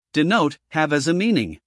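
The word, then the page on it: denote: uk dɪˈnəʊt; en-us dɪˈnoʊt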